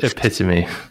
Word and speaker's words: epitome: It's pronounced epitome. – Really? Okay. Well, yes. I don't know. In French, you say epitome, so I'll say it that way.